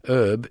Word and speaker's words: herb: Although in America, herb is still said with a silent H.